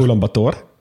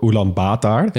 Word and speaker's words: Ulaanbaatar: Maar er, zijn dus, er is niet één manier van het uitspreken. – Je oh, dat is handig. – Ulaanbaatar en ook Ulaanbaatar.